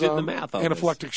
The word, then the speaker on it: anaphylactic: Now, we know that there's anaphylactic shock. Am I saying that right? – What is it? Anaphylactic, yeah.